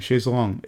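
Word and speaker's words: chaise longue: Its pronunciation is the same. Chaise long. It's pronounced the same in French as long in English, and it means long chair.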